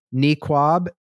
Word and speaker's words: niqab: They didn't ask a single person how to pronounce Neekwab, Neekwab. It's not IT. I think they said neat.